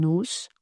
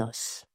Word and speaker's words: os: Le son O en syllabe fermée est généralement ouvert, donc plutôt que de dire un os, on dit logiquement un os.